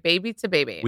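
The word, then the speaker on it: Baby2Baby: Am I saying it wrong now? No, you're saying it right. – Okay, baby to baby.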